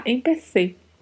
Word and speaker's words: empecé: Você vai colocar a língua entre os dentes para pronunciar empecé. MPC, que é diferente de MPC com som de S.